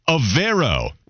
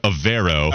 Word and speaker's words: Evero: Evero We've been pronouncing it wrong, but now we got it figured out. Ejiro Evero.